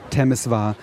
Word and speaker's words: Temeswar: Ich glaube, in Deutschland ist es weiter verbreitet, der Begriff Temeswar. Wir haben gelernt, Temeswar ist die deutschsprachige Aussprache und Timișoara die rumänische.